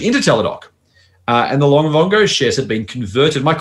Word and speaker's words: Livongo: I know in previous Shooting Stars picks, Doc had both companies Livongo, I think it's pronounced right, and Teladoc. Livongo has now been merged into Teladoc, and Livongo shares have been converted. Firstly, mate, before I keep going, am I pronouncing that correctly? Because if I'm not, mm-hmm. I'm, I'm repeat okay, good.